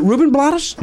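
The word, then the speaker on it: Rubén Blades: I always want to pronounce blades Blottis because of the actor Ruben Blottis. – He got in your head, didn't he? – Is it Ruben Blottis? – Yeah.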